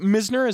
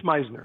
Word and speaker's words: Misner: Is it Misner? Is that how you say it? – It's Meisner. – Meisner, okay. – I'll respond to Misner. – No, I'll say Meisner. It's after all, the way it should be pronounced.